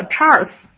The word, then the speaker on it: Charles: 但实际上在英文发音里面，这叫 Charles。